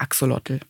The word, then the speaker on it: Axolotl: Das kommt aus der aztekischen Sprache und wird wahrscheinlich eher so ein bisschen Axolotl, irgendwie sowas ausgesprochen. Ich kann es leider gar nicht richtig. Da gibt es auch lustige YouTube-Videos dazu. Mhm. Aber in der Wissenschaft und im allgemeinen Sprachgebrauch sagen wir Axolotl.